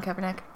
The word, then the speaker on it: Kaepernick: Kaepernick? – Colin Kaepernick.